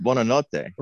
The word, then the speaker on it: Buonanotte: Why? It's pronounced Buonanotte.